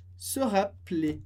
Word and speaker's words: se rappeler: A gente escreve com só um L, porque o som é se rappeler, se rappeler. Isso que você vai ver com até o R mais pra frente, que a gente tira o E e pronuncia simplesmente se rappeler. Claro que na escrita ele está ali, sempre presente, mas como tem só um R, a gente pronuncia o E muito rápido.